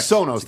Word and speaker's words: Sonos: Is that how it's Sonos? – Pronounced? – Correct. – Sonos.